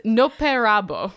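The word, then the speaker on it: Noperabo (のっぺらぼう): That's not how you pronounce that word. Hold on. – Oh, no, sorry. I was noping to the idea of the creature. I mean, also it's nope how you pronounce it, but it's Noperabo.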